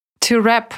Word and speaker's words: wrap: At the end of a year, her ladyship presented Barry with a son to wrap или to wrap up переводится как заворачивать. Кстати, пишется с буквой W в начале, но читается без нее.